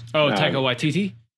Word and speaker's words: Taika Waititi: And it was, Taika Waititi. – Yeah. Taika Waititi, I love, which such a big fan.